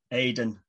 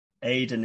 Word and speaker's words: Aidan: So it's at Aidan Severs, and Aidan is spelled A-I-D-A-N, as opposed to E-N, which is another common spelling of my name.